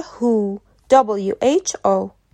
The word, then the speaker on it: who: البته لزوما هر لغتی که با WH شروع بشه این تلفظ رو نداره مثل لغت WHO, WHO